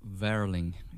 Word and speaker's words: Werling: My name is Andreas Werling. It's W. – See, because whenever Americans say W, they think it's I noticed like German too. – Yeah. – Like, W is some more like a V, right? – Yeah.